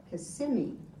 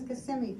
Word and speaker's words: Kissimmee: This isn't, kiss. this isn't me. it's Kissimmee. I've heard of this place. This is Kissimmee, Florida. And she goes, no, look how it's spelled, K-I-S-S-I-M-M-E-E, Kissimmee.